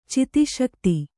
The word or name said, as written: ಚಿತಿಶಕ್ತಿ